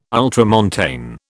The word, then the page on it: ultramontane: British English